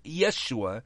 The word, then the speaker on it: Yeshua: Jesus, in Hebrew, is pronounced Yeshua, and it means deliverer.